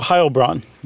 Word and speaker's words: Heilbronn: Is it Heil? – It is. I just goofed. In German, okay. you do the second syllable. – Okay. – For an E-I. – Okay. So Heilbronn. – Yeah, Heilbronn, sorry. – Double N at the end.